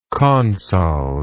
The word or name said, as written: consols